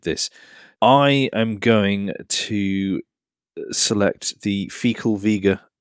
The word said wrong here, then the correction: Facel Vega: HK500. – Fecal. – Fas. Oh, Facel Vega.